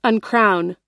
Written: uncrown

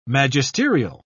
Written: magisterial

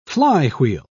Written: flywheel

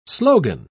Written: slogan

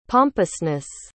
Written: pompousness

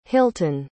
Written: hilton

